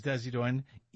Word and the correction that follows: Isaias: Isaias. – I see what you're doing. You're laughing at me. Hurricane Isaias. – There you go.